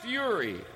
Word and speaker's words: Fieri: from the always talented Jeff Richards, he'll now be doing Guy Fieri.